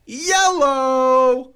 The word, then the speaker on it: Iello: But for those of us, tonight is all about yellow. – Yes. – Because we are going to basically be doing at least three, but potentially four games from the publisher, yellow.